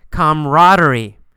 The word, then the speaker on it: camaraderie: Camaraderie. You really don't pronounce that second A. We usually say camaraderie.